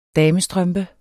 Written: damestrømpe

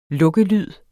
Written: lukkelyd

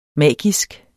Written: magisk

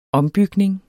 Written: ombygning

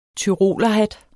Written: tyrolerhat

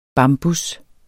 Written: bambus

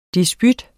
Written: disput

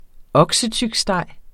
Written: oksetyksteg

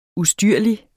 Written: ustyrlig

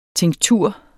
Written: tinktur

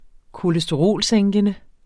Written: kolesterolsænkende

